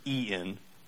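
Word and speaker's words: en (ἐν): that word in the Greek is, if you change it over, is en. So it's still pronounced basically in, but it's being baptized into Christ, into the Father, into the Son, into the Holy Spirit.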